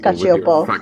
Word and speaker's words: Cacioppo: But there's two P's, so it's Cacioppo. I don't know. – No, it's just Cacioppo. You don't pronounce the P's twice.